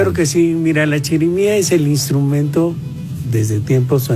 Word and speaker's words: chirimía: la chirimía, ¿verdad? ¿Sí lo dije bien? – Sí, es chirimía, sí.